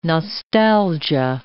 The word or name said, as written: nostalgia